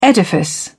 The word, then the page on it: edifice: British English